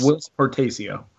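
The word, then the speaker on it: Whilce Portacio: I believe it's Wils Portacio. – His real name is William, but yeah, he goes by Wils Portacio.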